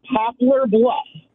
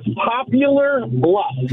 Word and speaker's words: Poplar Bluff: And I moved up this way in 2012, and it irks my soul and just like nails on a chalkboard when I hear people pronounce my hometown as Popular Bluff.